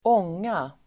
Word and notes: ånga: [²'ång:a]

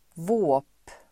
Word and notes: våp: [vå:p]

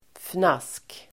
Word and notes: fnask: [fnas:k]

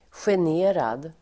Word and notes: generad: [sjen'e:rad]

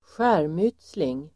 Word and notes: skärmytsling: [²sj'är:myt:sling]

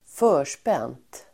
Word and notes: förspänt: ²f'ö:r_spen:t

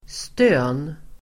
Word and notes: stön: [stö:n]

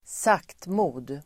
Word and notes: saktmod: [²s'ak:tmo:d]

